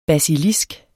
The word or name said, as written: basilisk